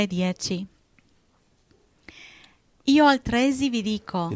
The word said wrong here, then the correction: altresì: No, non dice così. Dice. – Altresì? – Ecco. – Oh, sorry. – Guarda, accento. – Non mi riscrivere la lingua italiana, non mi rigirare Dante nella tomba. – Ops. Io altresì vi dico...